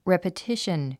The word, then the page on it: repetition: rèpətíʃən; レェパティシュン